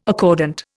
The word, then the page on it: accordant: Indian